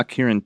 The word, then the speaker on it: Akirin: And we also discussed a little bit about a gene called, I'm not sure I'm pronouncing it right, but is it called Akirin? – Yeah, Akirin 2.